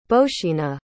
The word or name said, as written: bozhena